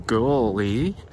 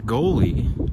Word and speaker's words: gully: The little gully, pronounced gully, like seagull. Gully, not goalie. Goalie.